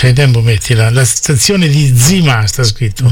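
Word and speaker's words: Zima (Зима): La stazione di Zima, però si pronuncia Zima in russo.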